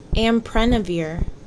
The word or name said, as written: Amprenavir